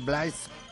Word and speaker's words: Blaise: Bueno, llegamos a la sección de Ha nacido un artista y hoy tenemos que celebrar que en 1623 nació Blaise, se escribe Blaise, se dice Blaise Pascal,